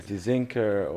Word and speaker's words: Tsinker: The struggle with my silent T in the front. So Tsinker, or they ask me how I spell it.